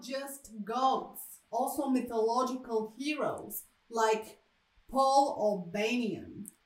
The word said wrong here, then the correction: Paul Bunyan: Paul Bunyan. – Paul Bunyan. – My wife is Bulgarian, so she doesn't quite know. She has just learned the proper pronunciation of Paul Bunyan.